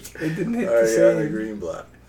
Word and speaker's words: Ariana Greenblatt: Ariana Greenblatt.